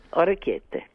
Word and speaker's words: Orecchiette: Orecchiette with clams Orecchiette. – And zucchini. Orecchiette. – Orecchiette.